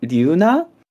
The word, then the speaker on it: Glüna: The series is called Ginka to Glüna, and it's really weird how the official spelling, like, this is not a thing that the translation does. It's the official one with the silent G.